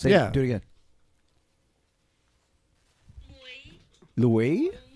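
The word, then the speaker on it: muối: Mui. It's like meow. Mui. It's an M. – Yes, M. It's spelled M-U-O with a caret. And a little slashy going up to the right, and an I.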